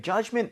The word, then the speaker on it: judgment: 자, 그러면 judgment 발음 한번 해볼게요. D 사운드 크게 내지 마시고요. 가보겠습니다. Judgment, judgment.